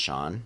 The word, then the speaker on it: Chaon: It's Dan, C-H-A-O-N, but it's pronounced Shaun.